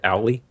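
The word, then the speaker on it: Owle: Owly? A-O-W-L-E, this accessory?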